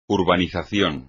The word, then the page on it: urbanización: espagnol